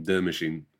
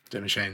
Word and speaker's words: De Machine: De Machine. So it's spelled D E space machine. – De Machine. De Machine.